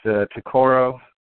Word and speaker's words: tokar: And there's all these people that are showing up trying to get in Danny's Tokar. That's how you say it. Trying to get in Danny's Tokar.